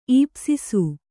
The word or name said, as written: ಈಪ್ಸಿಸು